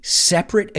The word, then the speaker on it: separate: Now, an interesting fact that I came across, que topé con, was that on three separate occasions, cuidado con la pronunciación, no separate, bueno, separate es el verbo, and separate es como diríamos el adjetivo. So, separate, separate.